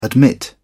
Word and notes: admit: British English

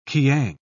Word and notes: kiang: British English

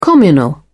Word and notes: communal: British English

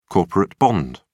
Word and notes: corporate bond: British English

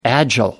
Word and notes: agile: American English

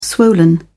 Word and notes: swollen: British English

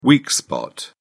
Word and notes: weak spot: British English

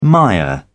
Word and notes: mire: British English

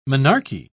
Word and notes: menarche: British English

